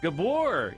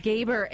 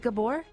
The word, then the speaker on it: Gabor: Gaber, it's actually pronounced Gaber in Tampa. – No, Tampa. It's Gabor. – Is it Gabor? – Yeah, like he says Gabor. – Like Zsa Zsa Gabor.